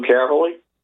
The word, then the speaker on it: Caverly: And you can write to me, Lou Caverly, and that's last name is spelled C-A-V-E-R-L-Y.